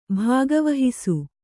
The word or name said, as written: ಭಾಗವಹಿಸು